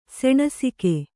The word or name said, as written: ಸೆಣಸಿಕೆ